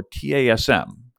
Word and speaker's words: TASM: Now, our favorite place for metrics that matter is the OWASP Threat and Safeguard Matrix, or TASM, kind of pronounced like TASM, like the Tasmanian devil.